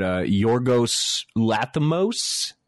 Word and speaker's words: Yorgos Lanthimos: But Yorgos Lanthimos.